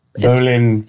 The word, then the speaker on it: bowline: So it's pronounced bow line, but bowline. – It's spelled bow line, but we pronounced it bowline.